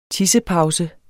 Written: tissepause

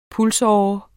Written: pulsåre

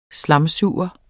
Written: slamsuger

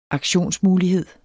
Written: aktionsmulighed

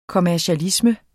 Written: kommercialisme

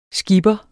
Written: skipper

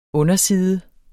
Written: underside

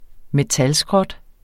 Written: metalskrot

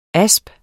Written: asp

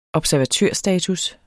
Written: observatørstatus

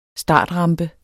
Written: startrampe

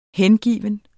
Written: hengiven

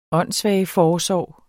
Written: åndssvageforsorg

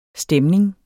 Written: STEMNINGERNES

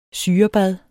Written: syrebad

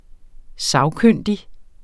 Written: sagkyndig

